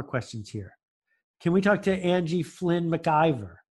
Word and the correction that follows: McIver: Did I say that right? – Yeah, it's actually McKeever. – But oh, McKeever. Okay, not like the TV show.